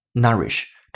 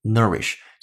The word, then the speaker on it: nourish: 今天讲解的重点单词自然是 nourish 这个单词，n o u r i s h，它的英音读法当中的 o u 字母组合读一个 r 的音叫。 nourish，它的美音读法倾向于一个 a 的音叫 nourish，所以这两种读音都是对的。